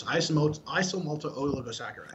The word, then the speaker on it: isomaltooligosaccharides: The quick side note on this is that Quest initially used to make their bars with something called IMOs, isomaltooligosaccharides.